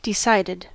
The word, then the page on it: decided: American English